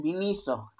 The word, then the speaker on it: Miniso: Dice que es sin acento en la O, entonces es el Miniso.